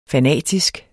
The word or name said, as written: fanatisk